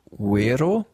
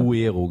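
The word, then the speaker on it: Güero: Ähm, es gibt auch ein Album von ihnen, das heißt, musste ich lernen, Uero. Uero? Obwohl es Gero geschrieben wird, aber. Uero geschrieben. Uero, Uero wird ausgesprochen.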